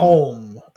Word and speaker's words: Colm: Is it Colin or Colm? – Oh, Colm. – Colm. Okay. I thought it was like Callum, but they were mispronouncing, but it's Colm.